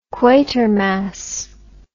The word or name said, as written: quatermass